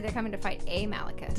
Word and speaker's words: Malekith: All of the one-shots and side issues tie into this big battle where all the Marvel heroes are coming together to fight Malekith, which I just saw in Thor Dark World.